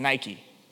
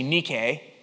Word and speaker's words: Nike: In the original Greek, it's Nike. We've been pronouncing it wrong. It's actually Nike.